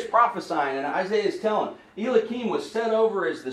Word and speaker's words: Eliakim: Eliakim. – I like the way you pronounce it better than the way I pronounce it.